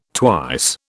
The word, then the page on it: twice: British English